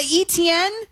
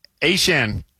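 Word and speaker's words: Achane: And so apparently, A-Chan, who was formerly, the artist formerly known as ETN, says that it's always actually been A-Chan, but we've always been pronouncing it wrong with ETN.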